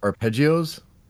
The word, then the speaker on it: arpeggio: I hope I'm pronouncing this properly. Please correct me if I'm not. arpeggios? – I think that was pretty close. I mean, it's arpeggios? Italian, right. So arpeggio.